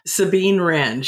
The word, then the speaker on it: Sabine Wren: And am I even saying her name right? – Sabine Wren.